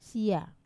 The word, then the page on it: sia: Wamesa